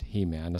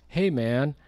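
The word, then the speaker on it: Heman: So it's not actually not pronounced He-Man, it's pronounced Hey-Man, but I call him He-Man, He-Man the worship leader.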